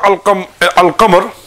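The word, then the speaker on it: al-qamar (القمر): The reason being is that in the word al-qamar, the lam is pronounced, isn't it? – Yeah.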